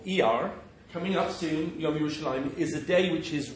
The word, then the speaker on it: Yerushalayim: I mean, the name itself, very clearly, even though it's written Yerushalayim with Shin Lamed Mem at the end, is always pronounced Yerushalayim.